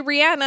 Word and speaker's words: Rihanna: Everybody says Rihanna, and it's actually Rihanna, and I try to respect that, but I'm not a jerk and I don't correct everybody else.